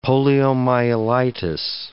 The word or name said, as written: poliomyelitis